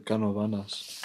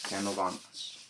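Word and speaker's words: Canóvanas: A few moments later, in August, an eyewitness, Madeline Tolinto, reported seeing the creature in Puerto Rican town of, how do you say that, Canavanes? Calvanes? Uh, oh, it looks like Canavanas. – Canavanas.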